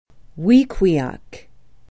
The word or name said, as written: wequiock